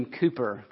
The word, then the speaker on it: Cowper: John Newton had a long-time friendship with another hymn writer, a man named William Cooper. Cooper's last name is spelled C-O-W-P-E-R. It's not how we pronounce Cooper, but...